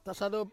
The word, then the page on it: sado: Ansus